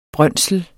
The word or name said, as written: brøndsel